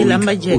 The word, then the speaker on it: Lambayeque: ¿Lambayeque está bien pronunciado? – Sí, Lambayeque.